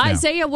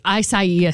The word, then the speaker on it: Isaiah: Isaiah was pretty rough for, you know, the panhandle. – Oh, you said it wrong. There you go. You said Isaiah. – Isaiah, excuse me. – Right, exactly. – Isaiah was pretty rough for Destin.